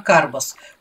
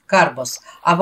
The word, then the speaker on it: карбас: Ну хорошо, пусть теперь у нас не ботик будет, а карбас. Ударение на первом слоге – карбас, а во множественном числе будет звучать карбаса.